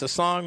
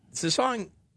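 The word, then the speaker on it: SsangYong: Some of them are under, I believe it's pronounced Sasong Motors, which was one of the early second-tier fallouts. Sasong was going to come to the United States.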